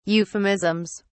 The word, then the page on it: euphemisms: British English